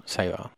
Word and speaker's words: Seva: Seva? Is that how you pronounce it? – Yeah. – Seva.